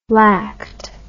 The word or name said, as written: lact